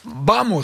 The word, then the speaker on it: vamos: Vamos, ça se prononce comme ça. – Tout à fait. – Le V se prononce B en espagnol, je sais pas, je parle pas espagnol.